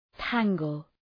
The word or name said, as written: tangle